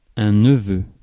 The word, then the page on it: neveu: [ɛ̃ nə.vø]; /nə.vø/